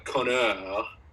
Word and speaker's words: Conor: So the way you properly say my name is Connor. And if you say it like with a Chicago accent, if you say like Connor with a Chicago and a French accent, Connor, mm-hmm. Throw an A on there instead of an O, it means asshole.